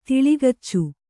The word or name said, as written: ತಿಳಿಗಚ್ಚು